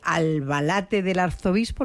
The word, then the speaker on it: Albalate del Arzobispo: Pero bueno, llegas a este pueblecito de Albalate del Arzobispo, ¿lo he dicho bien? – Sí.